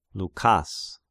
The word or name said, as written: Loukas